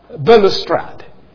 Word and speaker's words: balustrade: Of course, there are those who take this phrase as a reference to the temple balustrade. Or balustrade. B A L U S T R A D E. Balustrade or balustrade, either way you pronounce it.